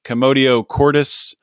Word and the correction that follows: commotio cordis: Um, commotio cordis. A, commotio, cordis. – I was close.